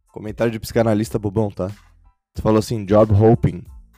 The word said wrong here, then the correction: job hopping: né? E a pronúncia correta seria job hopping, eu acho, né? Porque hopping é pular, né? Hopping é pular e hoping é esperança.